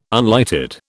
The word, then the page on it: unlighted: British English